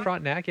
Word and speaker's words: Frontenac: How do you say it? Frontenac? I'm sure you know. – No, that's right. Frontenac, yeah.